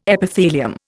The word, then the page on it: Epithelium: Indian